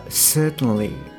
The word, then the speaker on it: certainly: であとですねあの前回触れてなかったんですけれどもえあの someday とか certainly ですねこちらもやっぱりあの音節がちょっと分かれているのでアクセント音節がある someday だったらまあ前ですよねで certainly まあこちらも前ですね